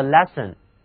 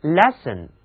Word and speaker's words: lessen: lesson 听起来我刚才好像是在说课程、课文那个单词 lesson，其实我说的 lesson 是 L-E-S-S-E-N。怎么样，这两个单词读音一样，形状只差一个字母，是不是也需要大家格外认真的去记忆呢？ L-E-S-S-E-N lesson 就是给 less 这个更少的形容词加上了动词后缀 en lesson，它的意思是减少、减轻，给学生减轻负担，这不是近几年来社会普遍关注的一个问题吗？